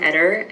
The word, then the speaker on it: Eter: Yes, my name is Jahane Eater, and that's spelled J-I-H-A-N-E, and my last name is E-T-E-R.